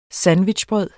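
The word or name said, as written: sandwichbrød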